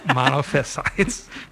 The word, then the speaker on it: Monophysite: Some people, in fact, probably most people pronounce it Monophysite. Okay. And we've had an argument at the seminary about how that's pronounced, and some have sided with me, saying that the accent is on the penultimate rather than, well, anyway. I'm just going to continue calling it Monophysites.